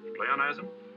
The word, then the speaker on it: pleonasm: Pleonasm? – Yes, that how you pronounce it? That's it.